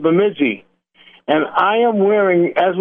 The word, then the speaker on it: Bemidji: Did I say that right, Bemidji? – Bemidji Woolen Mills.